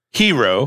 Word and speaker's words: gyro: So I said, here's a G-Y-R-O, spelled G-Y-R-O, pronounced hero.